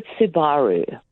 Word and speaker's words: Subaru: Well, I used to call it Subaru. – Yeah. – And then I think Subaru is probably right.